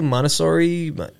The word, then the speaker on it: Montessori: Montessori. – Yes. Good pronunciation. – I finally got it. I thought I was gonna miss it because it's pronounced like Monte Cristo. It's like Montessori.